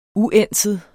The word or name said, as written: uænset